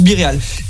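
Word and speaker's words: BeReal: Ouais, mais je pense que c'est biril, hein. Oui, oui, oui, non, mais oui, mais bon, il euh, y en a ici, ils prononcent biréal.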